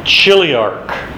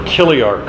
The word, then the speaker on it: chiliarch: The tribune is a commander, or literally, chiliarch. C-H-I-L-I-A-R-C-H. Chiliarch. Or kiliarch, however you want to pronounce it.